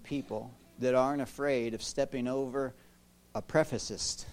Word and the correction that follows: precipice: I still said it wrong. A precipice.